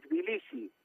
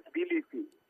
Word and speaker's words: Tbilisi: ¿Cómo se dice en castellano mejor? – En Tbilisi. – Tbilisi. Y tú eres el. – Se llama Tbilisi. – Tbilisi, perfecto.